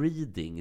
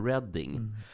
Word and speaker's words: Reading: Andra klassiker, Mats, är ju när folk säger Reading istället för Reading.